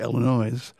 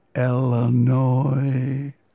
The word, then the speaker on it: Illinois: The state of Illinois, which he pronounced correctly. Some people say Illinois, but he spells it phonetically on the album, E-L-A-N-O-Y, Illinois.